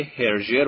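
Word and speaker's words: Hergé: در سال 1924 جورج رمی نام اختصاری هرژه رو که مخفف اسمش آر جی بوده به تلفظ فرانسه برای خودش انتخاب میکنه.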